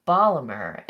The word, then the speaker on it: Baltimore: Baltimore, or Balmer, as my husband's family used to say, in the local pronunciation back in the day.